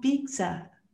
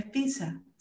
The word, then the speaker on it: pizza: Entonces aquí en Monterrey un, amigo me dice, pero es que pronuncias muy mal pizza, eh, porque le metes una T. Y yo, nunca me había dado cuenta, es pizza sin la T.